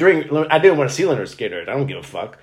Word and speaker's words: Lynyrd Skynyrd: Yeah, pronounced Lynyrd Skynyrd.